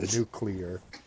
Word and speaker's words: nuclear: There's only one U in nuclear, just so you know. – All right, pronounce it for me. How did I say it again? – You said nuclear. – Nuclear. Oh, yeah, it's nuclear.